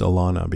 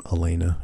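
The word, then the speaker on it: Alanna: The last thing that Lauren Cohan said to me was, um, "It's pronounced Alana because I was pronouncing Alana's name Elena during a panel."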